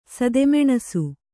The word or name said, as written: ಸದೆಮೆಣಸು